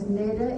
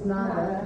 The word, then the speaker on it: Nada: I have an example that my name is Nada, N-A-D-A, and in Spanish it's Nada, Nada, and it means nothing.